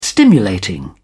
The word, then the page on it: stimulating: British English